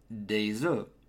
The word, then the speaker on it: des œufs: The pronunciation of egg in the singular form is œuf. Pronounce the final F. In the plural form, the final F and S completely disappear. Hence, to say some eggs, for example, you'd say des œufs.